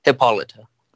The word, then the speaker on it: Hippolyta: Hippolyta.